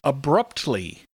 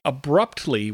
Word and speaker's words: abruptly: And our next pronunciation tip is abruptly. When you say abruptly, we have to make sure to hear both the P and the T clearly.